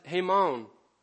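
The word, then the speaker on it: hamon (הָמוֹן): And the Hebrew pronunciation of that is hamon.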